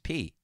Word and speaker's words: Peay: It's P-E-A-Y, right? – Right. – Yeah, it is. – But it's pronounced P.